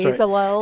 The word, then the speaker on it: Bezalel: Bezalel is the one that I like, th- that name. – Is that the I, one? Yeah, Because I like can't ever name? pronounce it. That's Bezalel.